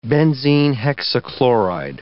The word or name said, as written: benzene hexachloride